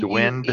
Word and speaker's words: duende: It's spelled D-U-E-N-D-E, Duende. – Duende. – Now, it could be the D, the E at the end could be silent, but D, Dwind. – E, N, D, E.